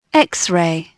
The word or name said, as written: XRAY